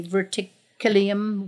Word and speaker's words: verticillium: Which means it's resistant to how do you say verticillium wilt? – Verticillium.